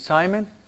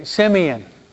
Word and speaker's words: Simeon: Is it Simon? Okay, Simeon. Pronounce prophecy of Simeon in Spanish, Simeon. Okay, Simeon.